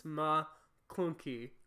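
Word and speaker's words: Ma klounkee: I read the article and it says that McClunky, it's really 2 words. It's ma clunky. – Like my clunky? – And it's not like clunky.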